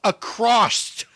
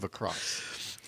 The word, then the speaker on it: across: So many times I've wanted to jump in the middle of it and go, stop saying across. Across Wisconsin. And I thought you'd say it one more time, and now you say it right and make me feel like a fool. I was a fool to doubt you, Todd Albaugh. You do know there's no T at the end of across.